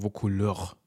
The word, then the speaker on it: Vaucouleurs: Ähm, die Festung, von der wir gerade gesprochen haben, heißt übrigens Vaucouleurs. Ja, das wollte ich nicht aussprechen. Und das finde ich eigentlich einen ganz schönen äh, Namen. Also der, der geht so Vocouleur, weißt du, das ist so Französisch für Leute, die eigentlich kein Französisch aussprechen können und deswegen, aber das.